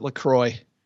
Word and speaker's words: La Croix: La Croix? Is that how you say it? La Croix? – Yeah, everybody I know calls it La Croix. Mm. But yes. But that's because we're American and we just...